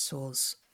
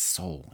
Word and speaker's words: Sowell: I think it's Sowell, isn't it? – Sowell? I think so. I keep trying to say Sowell, and then I see how it's spelled, and I can't do it.